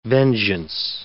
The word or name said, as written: vengeance